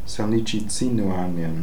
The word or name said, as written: Salnichizinooarnian